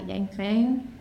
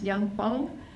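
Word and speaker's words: Yanfeng: First pronunciation is not Yangfeng, it's Yangfeng. Someone taught me that, think about saying the word phone.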